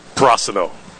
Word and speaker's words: Prosino: Okay, tell me the name, how you pronounce it again, because I was putting the accent on the second syllable, but it's Prosino.